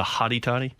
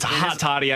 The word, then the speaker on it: hot toddy: Isn't it a hottie toddy? – It's hot toddy. I said it wrong.